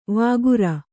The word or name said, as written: ವಕ್ರಿ